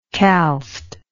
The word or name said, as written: calced